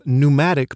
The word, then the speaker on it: pneumatic: More technically, we say that birds have pneumatic bones. There's a silent P in there. It's spelled P-N-E-U-M-A-T-I-C.